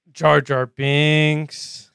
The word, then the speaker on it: Jar Jar Binks: Jar Jar Binkus. – Jar Jar Binks. – Binkus. – Jar Jar Binks. – Bink Misa wants you to pronounce it correctly.